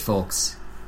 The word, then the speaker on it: folks: I've never met anybody who's pronounced the L so much. Folks. – Well, otherwise it's folks, and folks are the same thing. It's spelled the same. – Right, I've just never heard someone actually pronounce the L.